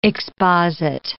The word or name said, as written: exposit